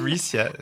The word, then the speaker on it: Bricia: Bricia.